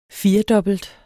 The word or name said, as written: firedobbelt